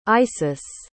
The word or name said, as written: isis